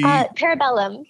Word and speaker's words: Parabellum: You were featured in a John Wick, I hope I pronounced this correctly, Parabellum film? Do you foresee... – Parabellum.